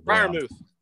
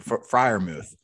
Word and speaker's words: Freiermuth: The Freiermuth. – Thank you, Freiermuth.